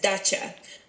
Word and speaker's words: Dacha: How do you pronounce it? – You pronounce it as Dacha.